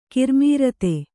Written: ಕರ್ಮಾರ್ಥ

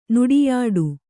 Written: ನುಡಿಯಾಟ

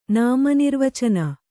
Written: ನಾಮನಿರ್ವಚನ

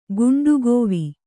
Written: ಗುಂಡುಗೋವಿ

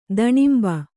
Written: ದಣಿಂಬ

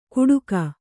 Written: ಕುಡುಕ

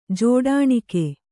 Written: ಜೋಡಾಣಿಕೆ

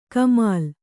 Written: ಕಾಮಿಲೆ

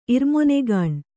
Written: ಇರ್ಮೊನೆಗಾಣ್

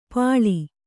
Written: ಪಾಳಿ

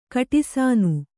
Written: ಕಟಿಸಾನು

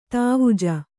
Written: ಧ್ವಜ

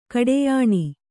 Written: ಕಡಿಯಣ

